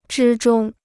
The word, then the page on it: 之中: zhī zhōng